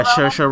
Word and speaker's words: Saoirse: Saoirse? Is that how you say it? – Saoirse Ronan.